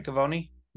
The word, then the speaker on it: Gavoni: Gavoni? – You got it, brother.